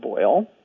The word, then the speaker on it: Boyle: Hi, my name is Bob Boyle, and it rhymes with tinfoil or motor oil.